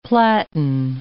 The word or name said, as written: platen